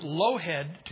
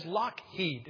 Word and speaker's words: Lockheed: This looks like Lowhead to you and I, but it's not. That is Lockheed.